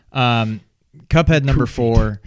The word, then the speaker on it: Cuphead: Uh, Kufid, I think is how you pronounce it. Uh, oh, sorry, Cuphead. Cuphead. um, Cuphead.